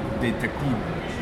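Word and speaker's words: Détective: and you would pronounce it Detective, you know.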